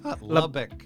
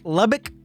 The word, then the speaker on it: Lubbock: It sounds like blah, blah, and I still don't know where it is or exactly how to say it. – Lubbock. – Lubbock. Lubbock. I thought it was, I was thinking it was French. I kept trying to say Le Blanc. Le Bleu.